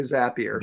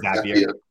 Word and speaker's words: Zapier: Maybe that's the first discussion point is how the hell do you pronounce it? – Well, they call them Zaps, so it's probably Zapier. – Zapier.